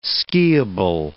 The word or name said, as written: skiable